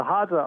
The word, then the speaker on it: Hadza: I don't even know how to say it, Hadza? Is that how you say them? – Hadza? That's right, the Hadza tribe.